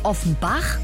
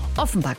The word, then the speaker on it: Offenbach: Ich habe immer gedacht, die werden Offenbach, so wie sie eben auch geschrieben werden, ausgesprochen. Aber nee, Offenbach.